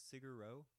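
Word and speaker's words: Sigur Rós: How do you say Cigaros? – I think it's Cigaro. Is how I say it. – No, you're right. It is Cigaro.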